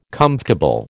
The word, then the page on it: comfortable: American English